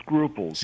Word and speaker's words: Sgruples: It's actually called Scruples. – Scruples, okay. – Scruples, because it's a play on the English word scruples.